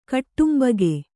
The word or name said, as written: ಕುಟುಂಬಿಗ